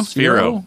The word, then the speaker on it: Sphero: by SP Hero? – Sphero. – Sphero. – Is that how you say it? – Yeah, Sphero.